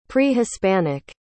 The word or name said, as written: pre-hispanic